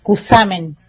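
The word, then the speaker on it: Cushamen: Por lo general, o generalmente, los cusameneros, cusameneras, decimos Cusamen, así como se escribe, nombramos de esa forma o leemos de esa forma.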